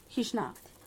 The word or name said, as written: GizhNat